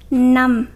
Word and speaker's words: năm: So as you know, the word five is pronounced num, right? But whenever five go after any other number, you will pronounce it lum. For example, 45 is bốn mười lăm. 55 is năm mười lăm. And just ask the just for now is lum, not num.